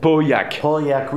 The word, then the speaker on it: Pauillac: Now that is the Pauillac. Did I pronounce it? – Pauillac region. – Yeah, exactly.